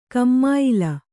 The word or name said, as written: ಕಮ್ಮಾಯಿಲ